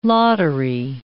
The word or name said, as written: lottery